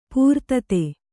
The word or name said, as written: ಪ್ರಥಿತ